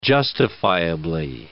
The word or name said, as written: justifiably